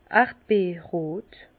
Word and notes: erdbeerrot: Bas-Rhin; Herrlisheim

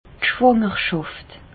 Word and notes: d' Schwàngerschàft: Bas-Rhin; Reichshoffen

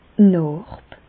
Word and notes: d' Nàrb: Bas-Rhin; Reichshoffen

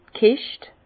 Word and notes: d' Kescht: Bas Rhin; Herrlisheim

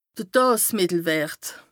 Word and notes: de Daasmìttelwërt: Bas Rhin

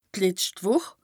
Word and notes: d' letscht Wùch: Bas-Rhin; Strasbourg

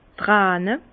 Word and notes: d' Träne: Bas Rhin; Herrlisheim

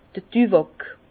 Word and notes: d'r Düwàk: Alsatian; Bas-Rhin; Herrlisheim

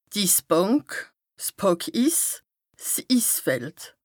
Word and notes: d' Issbànk, 's Pàckiss, 's Issfeld: Bas-Rhin; Strasbourg

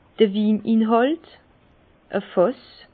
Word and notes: d'r Wininhàlt: Bas-Rhin; Herrlisheim

